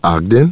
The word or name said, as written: Ogden